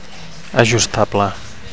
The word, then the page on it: ajustable: Catalan